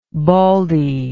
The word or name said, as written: baldy